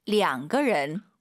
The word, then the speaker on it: 两: 两个人. – 우리도 이명이라고 안 하잖아요. 두 명 그러죠. 얼이 아니라 량. 어떨 때는 이거고 어떨 때는 이거야.